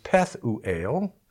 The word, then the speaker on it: Pethuel: Verse 1 tells us that Yoel was the son of Pethuel. I was thinking Pethuel. That's the Gentile pronunciation. But it's Pethuel, which in Hebrew means vision of God.